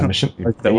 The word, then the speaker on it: Machinima: Like, if you go back and watch, if you watch the series on, how do you pronounce, Machinima? – Yeah, Machinima. You got it.